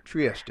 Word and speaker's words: Trieste: He also played the role of Danny Zuko in the Trieste? How do I say that? – Trieste.